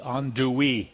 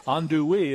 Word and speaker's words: andouille: And one of my favorite sausages in the whole world comes from Louisiana, and that is the andouille, or andouille, as you would like to say. Andouille is the way they pronounce it.